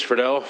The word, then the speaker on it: Friedel: I am Senator Chris Friedel, Senate District 26.